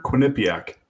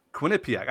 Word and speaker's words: Quinnipiac: or how do you pronounce that school? – Remember? Quinnipiac. – Quinnipiac. I said it right.